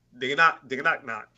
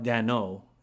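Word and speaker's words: Daigneault: And Chris Finch or Mark Dagenaut? – I'm pretty sure it's pronounced, and I speak French, Mark Dano, but...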